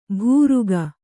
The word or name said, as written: ಬುರುಕ